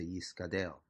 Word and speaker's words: Yisgadel: We don't say Yisrabe, we say Yisgadel. So if you want to, so since the first two words are in Hebrew, the correct pronunciation should be Yisgadel v'Yiskadesh. So why do some people have the custom to say Yisgadal v'Yiskadash? That would be the Aramaic vocalization.